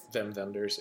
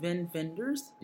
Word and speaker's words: Wim Wenders: The director is Wim Wenders. – Wim Wenders, yeah. – Oh, that's how you pronounce it? – Yep. – Wim Wenders? – Yep.